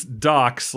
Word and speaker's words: DOX: It's Docs, like documentaries, but it's spelled D-O-X. – Oh. – It's spelled D-O-X, and I was like, what is this?